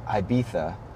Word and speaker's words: Ibiza: out in Ibiza, in Spain.